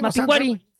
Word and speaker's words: Mapinguari: Ah, no, es que no tiene acento en la I. – No tiene acento en la I. – Mapinguari.